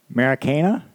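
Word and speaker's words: Maracanã: Maracana? – Yep, that one.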